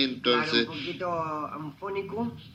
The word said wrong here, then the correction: afónico: ¿Afónico? Anfónico. – ¿Eufónico? – Anfónico. – ¿Cómo se dice? – Anfónico. – No. Afónico, de afoninga.